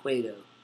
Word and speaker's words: Cueto: Cueto.